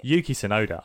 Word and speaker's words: Yuki Tsunoda: Yuki Tsunoda, I love him.